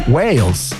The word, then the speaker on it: Wales: Este puente está Gay, no. Wales. – Sí, que yo no sé pronunciar Wales, digo gays.